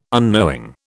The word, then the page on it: unknowing: British English